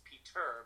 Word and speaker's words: Peter: Yes, in some countries it's Peter, but Peter is fine.